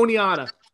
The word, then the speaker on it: Oneida: Oh, Oneida.